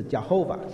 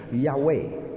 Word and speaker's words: Yahweh: 耶和华其实真正的念法不是 Jehovah，现在我们英文叫 Jehovah，其实原来的犹太文可能应该是念成 Yahweh。 Yahweh，所以他称 Yahweh 的时候就是指亲近的神，称 Elohim 的时候是指伟大的神。那这边是整个创世纪独有的一段，他是用 Yahweh Elohim，就是耶和华神，两个名字都放在一起。